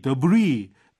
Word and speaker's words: debris: 아, 보통 우리 debris, D-E-B-R-I-S, S가 발음 안 되죠.